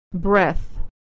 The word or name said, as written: breath